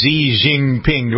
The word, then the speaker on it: Xi Jinping: Number 19 is Xi Jinping. Do I pronounce? I never pronounced that right, but no, that was right. That's perfect.